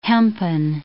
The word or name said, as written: hempen